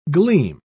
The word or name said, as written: gleam